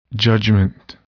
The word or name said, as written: judgment